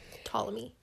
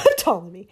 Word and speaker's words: Ptolemy: Ptolemy. – And Ptolemy. – P is silent, kind of. – Ptolemy.